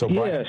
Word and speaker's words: O'Brien: Am I saying that right? – It's O'Brien. Yes.